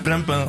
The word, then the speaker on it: Plimpin: Oui, tu reconnais directement les trompettes de la chanson Plimpin, je le dis bien ou pas ? – Plimpin, oui. C'est ça, de Salif Keita, le chanteur malien.